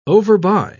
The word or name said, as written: overbuy